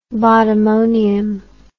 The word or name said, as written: bottomonium